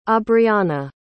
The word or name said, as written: aubriana